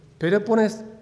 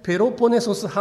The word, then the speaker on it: ペロポネソス: まあ、コリントというのはこのアテナの西側にありますペロポネスペロポネソス半島これ発音が難しいペロポネソス半島のこの北側にありますギリシャの都市なんですね。